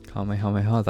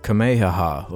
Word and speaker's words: Kamehameha: The Kamehameha. – Kamehameha, that is it. – Is that correct? – Yes. – Because I was listening to something and they pronounced it like Kamehaha or some shit, and I was like, what the fuck?